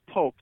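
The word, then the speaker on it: Poock: It is pronounced Polk. Like you're literally poking your husband or wife right next to you as you're listening to this in the car, and she's rolling her eyes at you.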